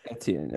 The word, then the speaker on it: Etienne: The Jaguars did end up taking Travis Etienne, or some are pronouncing it Etienne. – Etienne, I believe is correct.